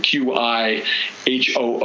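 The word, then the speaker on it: Qihoo: Also, another Chinese hyperscaler I was less familiar with called Qiho, which is spelled with a Q, Q I H O O, but is, is Qiho.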